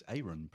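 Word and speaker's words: Aron: Elvis Aaron Presley there, arriving into the world. Thanks, Bethan. I did. There's the thing I learned from Peter Guralnick. It's not Elvis Aaron Presley. It's Elvis Aaron Presley.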